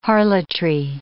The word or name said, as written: harlotry